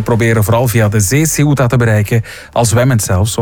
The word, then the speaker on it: Ceuta: Ceuta. – Ceuta.